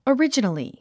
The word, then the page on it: originally: ərídʒən ə li; オリジナリィ